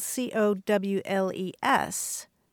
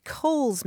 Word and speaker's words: Cowles: I would pronounce it Cowles Mountain. Right, Right. I know. And that's that's my problem because properly that mountain is pronounced Coles Mountain. It was named for a guy whose last name is Coles.